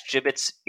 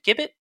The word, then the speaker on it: gibbet: Of course, the Halifax gibbet's invention was gibbet? Doesn't matter. You'll correct me in the comments. – I think it's a G. I think it's a hard G.